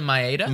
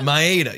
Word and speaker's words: Maeda: Kenta Maeda. – Maeda, yes.